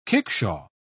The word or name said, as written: kickshaw